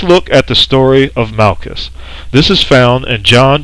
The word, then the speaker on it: Malchus: Now this word Malchus, which is spelled M-A-L-C-H-U-S, means king.